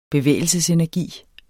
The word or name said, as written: bevægelsesenergi